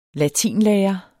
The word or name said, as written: latinlærer